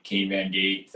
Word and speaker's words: Kane Van Gate: It rhymes with my name, Kane Van Gate, 38.